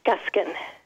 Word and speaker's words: Guskin: Yeah, Guskin.